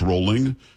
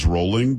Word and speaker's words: Rowling: Rowling. It's not. It's Rowling because she says Rowling.